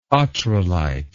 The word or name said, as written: ottrelite